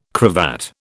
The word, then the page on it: cravat: British English